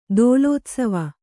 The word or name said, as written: ದೋಲೋತ್ಸವ